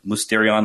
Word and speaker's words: mysterion: it's better pronounced like mysterion, like the Y is pronounced as a kind of U.